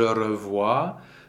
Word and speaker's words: revois: Je revois. The S is silent. Just in passing, the O-I combination is wa in French.